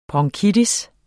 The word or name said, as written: bronkitis